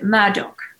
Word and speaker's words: Murdaugh: A very prominent family you pronounce it Murdaugh, but you spell it Murdaugh, M-U-R-D-A-U-G-H.